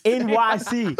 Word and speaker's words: Enyce: I just found out last year it was pronounced NYC. – Because it's spelled E-N-Y-C-E.